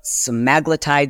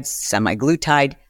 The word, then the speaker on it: semaglutide: Again, it's also a semaglutide, semiglutide. I hear it pronounced both ways, so I'm going to say it both ways.